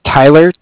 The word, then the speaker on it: Tyler: My name is Tyler, T-Y-L-E-R, okay. – And I'm calling with Cardiff. It's spelled C-A-R-D-I-F-F, as in Frank Frank.